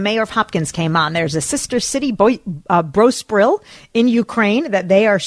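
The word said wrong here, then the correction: Boryspil: Did I say it wrong, David? If I said it wrong, correct me. – You did, but Borspiel, I think, is closer.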